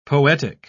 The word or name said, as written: poetic